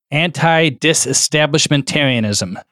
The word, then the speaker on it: antidisestablishmentarianism: It says anti disestablishmentarianism. – Say that one more time. – Anti disestablishmentarianism.